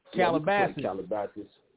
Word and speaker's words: Calabasas: Calabasas, am I saying that right? – Calabasas.